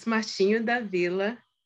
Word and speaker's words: Martinho da Vila: It's Martinho da Vila, Mulheres.